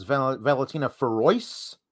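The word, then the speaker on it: Valentina Feroz: Lady Dusty match, which is there's Cora Jade and Raquel versus Valentina Ferois, which is how you pronounce it apparently.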